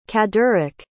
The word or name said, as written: caddarik